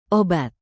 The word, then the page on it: obat: Indonesian